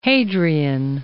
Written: Hadrian

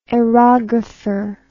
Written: aerographer

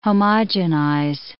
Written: homogenize

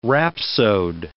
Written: rhapsode

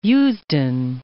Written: Eusden

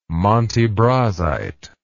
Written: montebrasite

